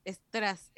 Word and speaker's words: estras: un chihuahua con collar de estras. Esa traducción me tiene pero mal. Podría haber sido de bisutería, de joyas eh, falsas, un collar brillante. No, estras. Estras. Estras. y con acento en la Estras.